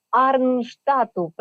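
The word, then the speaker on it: Arnstadt: Знаємо, що ви багатодітний батько, який переїхав до Арнштату, правильно сказала місто Німеччини? – Так.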